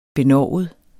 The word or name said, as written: benovet